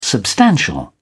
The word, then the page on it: substantial: British English